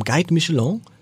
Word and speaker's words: Guide Michelin: Und der, um den es jetzt gleich gehen wird, der hat vom äh, Michelin, vom Guide Michelin, richtig ausgesprochen, als einer der ersten überhaupt einen Stern erhalten, und zwar einen grünen Stern.